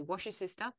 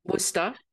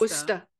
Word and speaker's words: Worcester: The only thing that she's really posted is she does things in, I can never say it. I'm going to say Washer Sister, Massachusetts. – Worcester. – It's spelled Worcester. – It's Worcester. It's Worcester.